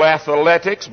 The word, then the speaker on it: athletics: They said A-T-H-E-A-T-H-L-E-T-I-C-S, athletics. But if you look up the word, you will find that it is correctly spelled A-T-H-L, and there's no vowel between that T-H and the L.